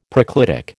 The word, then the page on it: proclitic: American English